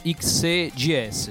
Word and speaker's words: XEGS: Cioè la 7800 e la XEGI, ce la farò a dirlo. La XEGS. – Dai, dai. – Come si pronuncia? Così? – XEGS, sì, che poi sarebbe appunto XE Gaming System, no?